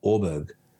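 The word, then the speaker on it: Åberg: My my Mo- no, apparently the correct pronunciation is like Orberg. Orber.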